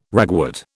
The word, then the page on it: ragwort: British English